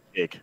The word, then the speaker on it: Jake: Jake. Yeah. – Oh, it's Pat's just Jake. Oh, okay. Oh, it's Jake backwards.